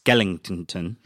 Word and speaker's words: Skellington: By the way, it's pronounced Skellington.